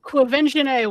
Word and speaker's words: Quvenzhané: Quvenzhané Wallace. – That's it. Quvenzhané Wallace.